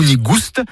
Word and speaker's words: nigoust: Nigou nigoust, avec prononciation du S originel que l'on retrouve dans le provençal goustus, goûteux, et qui a été remplacé depuis par l'accent circonflexe dans notre orthographe moderne.